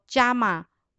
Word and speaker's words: yama: 读作伽马 Y A M A，它有规律、规则、自律、控制和规范的意思，是英文的 don't，不应该做的一些事情或行为，也可称作外在行为控制。